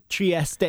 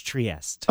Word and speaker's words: Trieste: Trieste in Italian, or in English, Trieste.